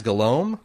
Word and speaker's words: Guillaume: Guillaume is it Guillaume? – I would assume. Yeah. – G-U-I-L-L-A-U-M-E. – Yeah. – Is Guillaume? – Guillaume. – Uh-huh.